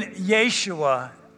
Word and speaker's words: Yeshua: That means I believe in Yeshua is the Messiah. I also believe that he was God incarnate, come into the world as the Savior, which is what Yeshua means.